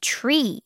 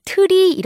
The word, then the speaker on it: tree: Tree 라고 하죠? 이거 발음할 때 친구들 트리 이렇게 하지 마세요. 트리? 자, 이게 아니라 tree. Tree 이렇게 발음해주면 되겠습니다.